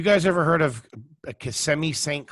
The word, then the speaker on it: Kissimmee: Kissimmee. – And it's Kissimmee.